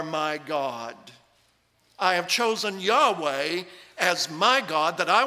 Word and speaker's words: Yahweh: But that tetragrammaton, YHWH, he's saying it's almost certain they pronounced it as Yahweh.